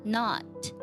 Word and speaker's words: knot: 在读这句话的时候呢，我们要注意一个单词，它读作 not，这是美式的发音，英式的发音读作 not。通常来讲呢，在英式发音当中读短音 od，在美式发音通常会读作啊这样的一个音。那么这个音发的时候呢，它是介于啊和 o 之间的一个音，不能读作 not，也不能读作 not，读作 not。 Knot, knot.